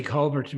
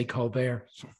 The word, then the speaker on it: Colbert: Sorry, Scott, I like the way you say Colbert, which is the way the Irish pronounce it, and the Americans tend to say Colbert.